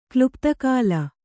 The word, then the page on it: ಕ್ಲುಪ್ತಕಾಲ: Kannada